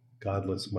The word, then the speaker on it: Godlis: Godless Miami, and that's actually his name, but it's spelled G-O-D-L-I-S.